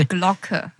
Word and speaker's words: Die Glocke: We talked about the secret space program, UFOs, the bell or die Glocke. Am I saying that correctly? – Die Glocke. – Yeah, that.